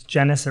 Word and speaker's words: Jenness: Yes, I always say to my patients, it's Jenness. It rhymes with tennis.